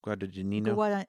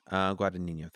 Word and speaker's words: Guadagnino: Guadagnino? – Guadagnino. – Guadagnino, thank you.